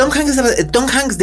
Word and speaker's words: Tom Hanks: Tom Hanks, de hecho, es interesante que Tykwer tenga a Tom Hanks como su protagonista, porque estrellita aquí, dorada por pronunciación.